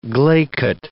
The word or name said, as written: glaiket